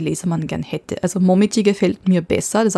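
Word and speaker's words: Momiji (もみじ): Die japanische Lesung ist Momiji. Momiji. Die chinesische Lesung ist Koyo.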